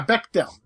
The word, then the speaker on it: Bechdel: Bechdel. – Yeah, Bechdel. That's how it's pronounced.